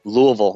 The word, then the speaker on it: Louisville: So we're so glad that you're here through Skype, and you're actually, your location is, we're just learning how to say it, Louisville. – Yes, Louisville. That's right, Louisville, Kentucky. – Did I say it decent? I should have shoved rocks in my mouth. – Like I said, you got to say it like your mouth's full of food.